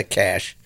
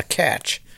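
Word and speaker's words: cache: Huh, it is a cash. A catch. Like catch. It's got like a TH sound on it, right? – No. It's just cash. Like cash money. It's pronounced that way.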